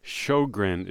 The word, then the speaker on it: Sjogren: S-J-O. G-R-E-N. Shogrin is how they pronounce it.